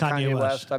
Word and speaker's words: Kanye West: Am I saying that right? – Kanye West.